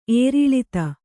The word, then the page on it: ಏರಿಳಿತ: ēriḷita